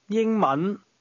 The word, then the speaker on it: 英文: 英文.